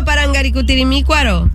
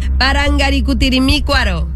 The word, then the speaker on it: Parangaricutirimícuaro: Ok, repite conmigo, Parangaricutirimícuaro. – Parangacutirimícuaro. – Vamos otra vez. – Te sigue faltando una sílaba. Parangaricutirimícuaro.